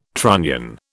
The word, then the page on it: trunnion: British English